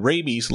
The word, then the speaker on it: rabies: Now, rabid has a short A and a short I sound, rabid. But rabies has a long A and a long E sound, so the two are quite a bit different in that respect. Rabid and rabies.